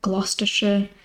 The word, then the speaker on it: Gloucestershire: The other one is S-H-I-R-E. What people would pronounce as Shire is actually a sh. So Lancashire, Gloucestershire, Hampshire. Again, I don't know why we pronounce it like that, but it's a thing that's universal across the UK, pretty much.